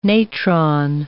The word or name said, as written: natron